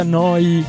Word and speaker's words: Neu: Si scrive Neu, si pronuncia Noi, e vuol dire nuova emittente urbana.